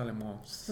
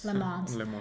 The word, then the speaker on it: Le Mans: Tu, tu, pronunciación francesa, Le Mans. – Le Mans.